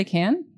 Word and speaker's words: Cannes: Do you say Cannes or Con? Do you say Can? – Yeah. – Ask the French guy. What do you say? You just say Can? That's right? Can? I'm saying it wrong, right?